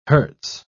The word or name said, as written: hertz